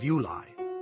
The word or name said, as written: Ulai